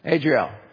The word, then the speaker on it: Adriel: Adriel, is that fair pronunciation? Adriel.